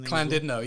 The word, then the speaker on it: Llandudno: Llandudno, yeah. Welsh, so they pronounce it Llandudno.